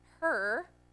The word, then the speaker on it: Hur: Hur, there you go, I said it right that time.